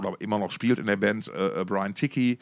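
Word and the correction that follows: Tichy: äh, oder Tichi wird es ausgesprochen,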